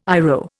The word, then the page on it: IRO: Indian